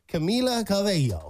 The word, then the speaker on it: Camila Cabello: Trying to figure out, make sure we're saying her name right. That's like the number one thing that everybody's saying. – Absolutely. – Camila Cabello and all this different stuff. So we gave it a shot. Camila Cabello. I'm saying that right. Right, Camila? – Yeah, you're saying that the first one? – Camila. – Say it again. – Camila? – Yeah, it's good.